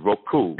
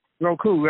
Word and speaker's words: Roku: I always say that wrong. It's Roku. – Roku, right.